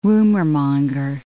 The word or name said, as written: rumormonger